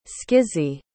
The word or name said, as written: schizy